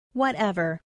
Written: whatever

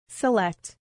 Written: select

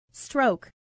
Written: stroke

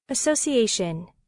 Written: association

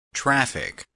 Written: traffic

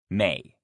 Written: may

